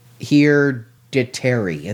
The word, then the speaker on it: Hereditary: I said Heredity. – It is Heredity. – Hereditary. There's an R in it.